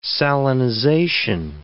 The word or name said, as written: salinization